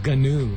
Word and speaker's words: gnu: So I always thought it was new. I didn't know that you were supposed to pronounce it Gnu.